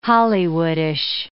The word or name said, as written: Hollywoodish